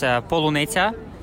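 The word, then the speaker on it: polunetsia (полуниця): They ask them to say a couple of words in Ukrainian, and there's a word strawberry, it's polunetsia, they cannot pronounce it, and that's how they find out if he's a Russian spy or Russian soldier.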